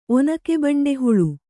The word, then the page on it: ಒನಕೆಬಂಡೆಹುಳು: onake baṇḍe huḷu